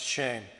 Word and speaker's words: shem: So now the word name comes from the translated Hebrew word shame, and that's spelled S-H-E-M. Now it's pronounced shame. Now this word has absolutely nothing to do with the English word shame.